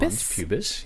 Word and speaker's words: mons pubis: The monus pubis? Pubis? – Mons pubis, yeah. – I never say that right.